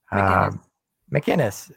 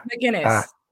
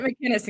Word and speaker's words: McInnes: So this is written by Joanna McInnes. I don't know. – McInnes. We pronounce it McInnes in Canada this way.